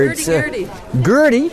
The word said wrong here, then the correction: hurdy-gurdy: Hurdy-gurdy. – Hurdy-gurdy. – Yes. Perfect pronunciation.